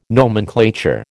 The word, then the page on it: nomenclature: American English